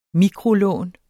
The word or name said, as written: mikrolån